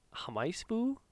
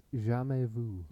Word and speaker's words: jamais vu: Something that I found called, I'm going to call it Hamaisbu. How would you pronounce that? – I think it's French. I think it's jamais vu.